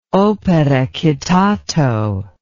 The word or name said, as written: opere citato